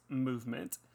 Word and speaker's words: MVMT: With fair prices, unexpected colors, and clean original designs, MVMT, pronounced movement, grew into one of the fastest growing watch brands, shipping to 160 countries.